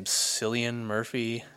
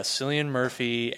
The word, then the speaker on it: Cillian Murphy: It's with, I don't know how to say his name, Cillian Murphy? – Yep, that's right. – Yeah, Cillian Murphy, and actually Captain America Chris Evans is in it.